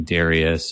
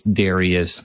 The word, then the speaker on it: Darius: you actually get the response from King Darius. And that's just a note to the listeners. That's one name that you'll hear pronounced at least three different ways. Darius, Darius, Darius.